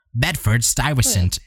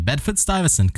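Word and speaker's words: Bedford-Stuyvesant: Bedford Stuyvesant is what she says in... – Right. Oh, okay. – But anyway, Bedford Stuyvesant.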